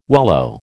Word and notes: wallow: American English

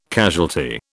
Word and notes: casualty: British English

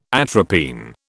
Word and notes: atropine: British English

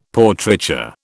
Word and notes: Portraiture: British English

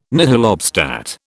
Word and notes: nihil obstat: British English

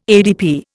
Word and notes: ADP: Indian

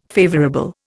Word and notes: favourable: Indian

